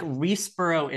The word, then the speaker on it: Riseborough: Yeah, like Reesborough is like a little hamlet in England. Riseborough is like a town in New Jersey.